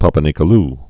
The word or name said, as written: Papanicolaou test